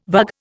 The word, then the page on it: vug: Indian